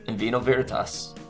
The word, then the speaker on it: In Vino Veritas: In Vino Veritas? – In Vino Veritas.